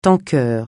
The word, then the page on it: tanker: francais